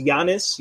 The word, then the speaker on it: Giannis: It's first of all, it's Giannis. You don't pronounce the G.